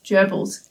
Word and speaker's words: Goebbels: Uh, it's specifically it's a um, German last name uh, referring to a, it's a Nazi politician Joseph Goebbels. Gerbils. – Because uh, I was looking at the spelling, going, and I'm pretty sure it'll be gerbils, but it's not spelled like the animal.